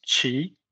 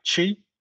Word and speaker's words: Chi: My Chinese name is Qi. So C-H-I, Qi. But we have different tones in Chinese. We have four different tones.